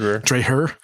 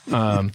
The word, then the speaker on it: Dreher: The Benedict Option by Rod Dreher, I believe is the right way to say his name. – Yep. Rod Dreher. – Dreher.